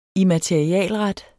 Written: immaterialret

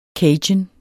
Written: cajun

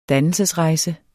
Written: dannelsesrejse

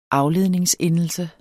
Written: afledningsendelse

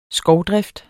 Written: skovdrift

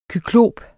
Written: KYKLOPERNES

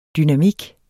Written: dynamik

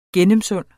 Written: gennemsund

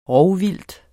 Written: rovvildt

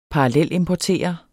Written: parallelimportere

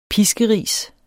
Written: PISKERISENE

